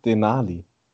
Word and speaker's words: Denali: Das hast du jetzt einfach nur falsch ausgesprochen, das alles. Das ist weder Essen noch Berg. Nee, wie heißt es? Denali.